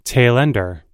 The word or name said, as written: tailender